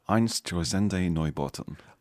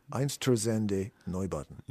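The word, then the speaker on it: Einstürzende Neubauten: Einsturbats. Oh. I think it's Einsturzende Neubauten. Einsturzende Neubauten. Yeah.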